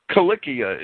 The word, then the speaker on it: Cilicia: Cilicia is the proper pronunciation. I don't know how it's pronounced in the mainstream. I don't care. It's in Greek, they were K's. They were hard. In Latin, they were C's, but the Romans pronounced the C as a hard letter, not as a soft letter. The soft C, like an S, is a more modern ecclesiastical invention.